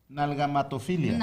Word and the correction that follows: agalmatofilia: No, agalmatofilia.